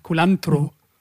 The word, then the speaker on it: Culantro: Hier also jetzt nochmal ganz kurz mit der übrigens viel schöneren Aussprache als meiner von Culantro. Culantro.